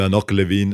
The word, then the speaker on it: Hanoch Levin: Hanoch Levin, insomma dipende se volete pronunciarlo, come dire, con accento israeliano, ebraico oppure no, oppure se invece preferite pronunciarlo con accento anglosassone, perché visto che ha...